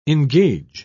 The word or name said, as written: engage